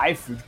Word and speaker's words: Liefeld: A gente vai falar um pouco da carreira do Rob Liefeld, ou Liefeld, cada um pronuncia de um jeito aí.